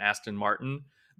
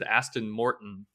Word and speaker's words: Aston Martin: Aston Martin, but I, I somehow mispronounced. I said Aston Morton.